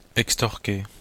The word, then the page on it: extorquer: [ɛkstɔʁke]; Belgique (Brabant wallon)